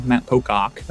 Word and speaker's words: Matt Pocock: You said it pretty American. I'm not going to lie. Matt Pocock. But I'll take it.